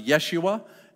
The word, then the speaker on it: Yeshua: There's a kind of a combination of Hebrew and Aramaic that would be pronounced Yeshua.